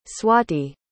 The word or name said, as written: Swati